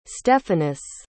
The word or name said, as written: stephanus